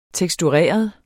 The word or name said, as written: tekstureret